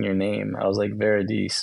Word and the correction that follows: Veridise: or just mispronouncing it. And so Shalini was always correcting me. And so literally I was like, oh, like very nice, Veridice. That's actually how I was remembering how to pronounce your name.